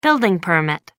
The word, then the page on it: building permit: American English